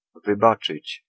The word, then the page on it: pardonner: France; [paʁ.dɔ.ne]